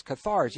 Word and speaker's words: Cathars: Now, the Cathars, is that how you pronounce it? Cathars, yes. Yeah, I really enjoyed reading about...